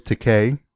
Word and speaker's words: Takei: What was funny too was George Takei, which mm-hmm. rhymes with gay.